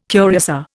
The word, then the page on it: curiosa: Indian